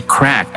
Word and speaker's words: craic: But before that, um, let's discuss what do we mean by crack, as in C R A I C.